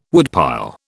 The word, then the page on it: woodpile: British English